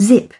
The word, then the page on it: zip: British English